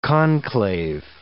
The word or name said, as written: conclave